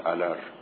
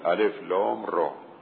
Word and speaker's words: الر: الف لام را خب این مقطعه هست دیگه، ترکیب نمیشه، نمیگیم الر، بگیم الف لام را.